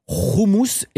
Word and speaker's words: houmous: Donc il faut établir une règle, on dit donc roumous et pas houmous.